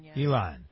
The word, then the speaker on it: Ilhan: Ilhan Omar.